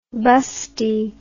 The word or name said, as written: basti